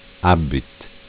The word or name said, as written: Abitt